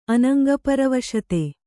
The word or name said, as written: ಅನಂಗಪರವಶತೆ